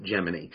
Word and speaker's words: Gemini: It's pronounced different ways, but usually people involved in the space program pronounce it Gemini.